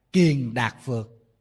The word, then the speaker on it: kiền đạt phược: Mà nguyên văn tiếng Phạn đọc là kiền đạt phược.